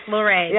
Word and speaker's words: Luray: I was there broadcasting the event live from Daryl Haley, the amazing Daryl Haley, from his bed and breakfast in Luray, Virginia. Did I say it right? I think I did, right? – Yes, Luray. Yeah, that's correct.